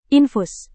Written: infus

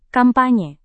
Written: kampanye

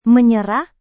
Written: menyerah

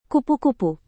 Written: kupu-kupu

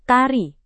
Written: tari

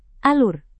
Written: alur

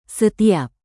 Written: setiap

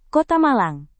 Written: kota malang

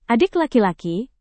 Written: adik laki-laki

